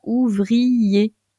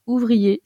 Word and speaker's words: ouvrier: Vous ne dites pas ouvrier, vous dites ouvrier, c'est-à-dire que ça fait qu'une syllabe et non pas deux.